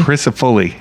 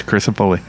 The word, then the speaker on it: Cristofulli: It's weird. It's a common thing, but it's actually you know, spelled phonetically, so it's Cristofulli.